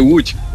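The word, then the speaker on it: Łódź: Ya aquí tendremos que volver entonces al Otso, bueno, como la pronunciación en polaco se dice correctamente, Wuch.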